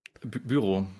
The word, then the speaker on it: Bureau: Naja, es ist am einfachsten für die Deutschen tatsächlich. Also meistens denkt man halt, dass es irgendwas Französisches ist. Und dann siehst du da irgendeinen Rainer, 45, der sagt, Collector Büro. Dann sagst du, nee, ich spreche einfach Büro aus, dann passt das.